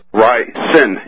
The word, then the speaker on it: ricin: Ricin. R-I-C-I-N. You've been inserting an A after the I. Don't do that.